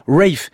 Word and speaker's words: Ralph: Il y a quelques années, plusieurs auditeurs m'avaient gentiment signalé l'exacte prononciation de son prénom. Ce n'est pas Ralph, mais plutôt Ralph. – Ralph Vaughan Williams.